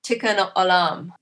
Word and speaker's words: Tikkun Olam: Tikkun Olam. Sorry, tell me how to pronounce that. – It's good. You did good.